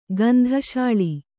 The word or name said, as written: ಗಂಧಶಾಳಿ